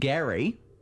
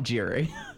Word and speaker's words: Gerry: Elbridge Gerry, not Jerry.